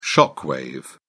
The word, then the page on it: shock wave: British English